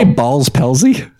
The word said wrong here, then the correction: Bell's palsy: Yeah, Bell's Palsy.